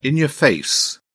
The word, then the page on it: in-your-face: British English